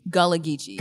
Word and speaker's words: Gullah Geechee: Um, uh, and I, you'll tell me if I'm pronouncing it right or wrong. Uh, Gula Geechee? – Gullah Geechee. – Gullah Geechee. – Yeah, so, Gullah Geechee.